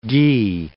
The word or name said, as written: ghi